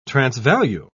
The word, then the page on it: transvalue: British English